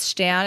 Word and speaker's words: Stern: So um, it's in German, it's pronounced Stan, and so I I can't help it. He's Stan. He's just Stan.